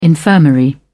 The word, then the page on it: infirmary: British English